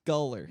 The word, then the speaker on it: Goller: Macy Goller. – Kind of. It's Macy Goller. – Goller. – Like, goal. Like goalie. I just scored a goal. I am a goalie.